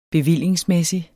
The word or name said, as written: bevilling